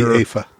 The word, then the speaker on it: Aoife: That'd be Aoife. – Aoife? – Yeah. – Okay. Spelled A-O-I-F-E. – Well, E-O-I-F-E, but it's pronounced Aoife. – Okay. Well, her name is spelled A-O-I-F-E.